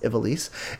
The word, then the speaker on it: Ivalice: But yeah, Final Fantasy 12 takes us back to the world of Ivalice, or Ivalice, however you want to pronounce it. I think it's pronounced Ivalice. And at least that's how they pronounce it on the game.